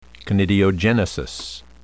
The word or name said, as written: conidiogenesis